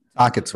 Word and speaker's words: Tocchet: Tocchet's right. It's Rick Tocchet.